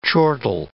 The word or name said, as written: chortle